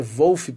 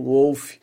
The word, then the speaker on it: Wolf: que é o Wolf Hoffmann, que inclusive me falaram que a pronúncia certa é Wolf porque ele é alemão. Mas aquela coisa, né, gente? É, a gente ouve a pronúncia das entrevistas, o cara tá nos Estados Unidos e fala um Wolf.